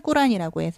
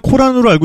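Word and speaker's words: 꾸란이 (القرآن): 우리는 코란으로 알고 있는데, 네. 아랍 발음은 꾸란이 맞습니다.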